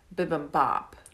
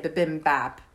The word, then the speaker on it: bibimbap: And so a little while ago, we had discovered bibimbap, which I'm saying it wrong. – Bibimbap. – Yeah, but I always say bibimbap because that's how it's spelled.